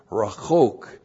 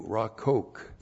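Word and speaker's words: rachok (רָחוֹק): And then it's that's modified by the adjective rachok, which looks like this. R-A-C-H-O-Q, rachok.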